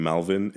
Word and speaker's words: Malwyn: It's because of my name Malwyn.